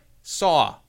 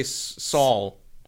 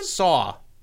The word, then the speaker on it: saw: Saw. I don't have an L. – Saw. – You say Saul. And Abby does it too. Like anyone. Anyone. – How do you say it again? – Saw. – Saw. – Yeah. Saw. There's no L in that word.